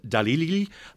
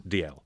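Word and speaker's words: Dalziel: The correct pronunciation is D.L. I mean, the easiest way to remember it is that it's a Scottish name. – Uh-huh. – And any Scottish name, surname that begins with D and ends with L, no matter what letters you have in the middle, it's D.L.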